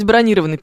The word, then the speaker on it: бронированный: Правильно говорить, бронированный, пишет Борис.